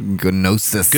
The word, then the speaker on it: Gnosis: Gnosis. – Gnosis. It's spelled G-N-O-S-I-S. – Serpent of Gnosis. The G's probably silent. – It is. You know what? It probably is.